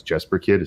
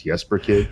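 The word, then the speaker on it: Jesper Kyd: She was reviewing the new EP that was in advance of the new Assassin's Creed Valhalla game, talking about Jesper Kidd, which I guess I'd been mispronouncing all this time as Jesper Kidd. It's Jesper Kidd.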